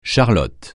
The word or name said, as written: charlotte